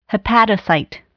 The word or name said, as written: hepatocyte